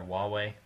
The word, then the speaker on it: Huawei: But I know there's other systems like Huawei, or rather Huawei, that's how you pronounce it, kind of the Chinese manufacturer.